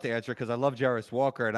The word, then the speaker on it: Jarace Walker: Jairus Walker.